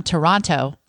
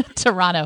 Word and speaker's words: Toronto: But she said, you can always tell when they're lying because they'll say, I'm from Toronto. Yes. So you said it right. I know you're real. – The other T, for sure. – Toronto.